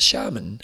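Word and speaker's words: Xiamen: Last week we were trying to say that Chinese race, and he's saying it's pronounced shaman. – Shaman. Shaman. Remember that all the Chinese are sha. So it's X-I-A-M-E-N.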